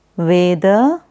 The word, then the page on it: Veda: Sanskrit